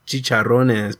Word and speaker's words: chicharrones: Am I saying that wrong? – Yeah, chicharrones, pendejo. – Excuse me. I said it exactly the same way. I just didn't roll my R.